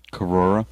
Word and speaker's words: Corora: Corora. Okay. – Because there's an extra syllable in there that the Cora is just missing. – But if you move your head kind of fast, it sort of blends in together because it's just O-R-O-R. Corora.